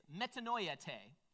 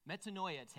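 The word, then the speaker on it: metanoeite: The Greek word for repent is metanoiate. Metanoiate. That's how Jesus pronounced it.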